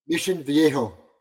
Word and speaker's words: Mission Viejo: Mission Viejo.